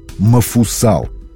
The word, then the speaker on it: Мафусаил: Но самым долговечным из десяти известных допотопных долгожителей по линии Адама – был Мафусаил. С таким произношением его имя вошло в нашу речь, хотя в церковнославянском и в русском синодальном переводах Библии его имя звучит как Мафусал.